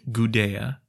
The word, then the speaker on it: Gudea: That light was shown by the Ensi of Lagash, a ruler named Gudea, spelled with a D, even though his name sounds very similar to the people who had taken over Akkad before him.